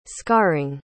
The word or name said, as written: scarring